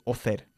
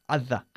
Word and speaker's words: other: We don't say author, it's other.